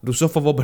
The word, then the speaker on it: rusófobo: Discurso rufobo, rufo, rusófobo, perdón, me da que lo dije mal antes.